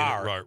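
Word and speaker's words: Rahr: I don't have my glass on, executive director of the RAR West. Am I saying that right? – You are saying RAR.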